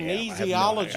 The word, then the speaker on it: kinesiology: She's going to college to be a, she'll eventually in 3 years, she'll be a doctor of exercise science and I think kinesiology, but I may be making that last part up. I probably kinesiology.